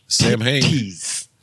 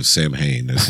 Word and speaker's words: Samhain: No, Sam Haines. It's not how you pronounce it. It's like Samhain. Samhain. Like that. It's like got a silent W.